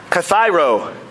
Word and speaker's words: kathairo: Kathairo is how that's pronounced in the Greek language, which when it translates to an English word, it's cathartic.